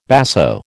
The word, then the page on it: basso: American English